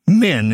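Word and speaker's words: men (מין): It's the Hebrew word men, it's usually pronounced.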